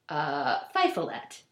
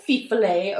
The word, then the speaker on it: Fifolet: So it's spelled F-I-F-O-L-E-T. So I was like, Fifolette. Fifolette. Like, Fifle the mouse. – Uh-huh. – But smaller. So yeah. – Fifolette. Which is untrue, totally untrue. So it's actually pronounced Fifolet.